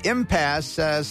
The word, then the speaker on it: impasse: And the last one is impasse, silent E, impasse.